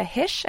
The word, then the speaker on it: Hische: They can find me at my first name, last name, which is Jessica Hish, and Hish is spelled H-I-S-C-H-E on Instagram or Twitter.